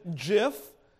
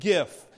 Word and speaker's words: GIF: There are those who pronounce it jiff, and there are those that are correct and pronounce it gif.